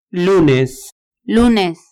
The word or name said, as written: lunes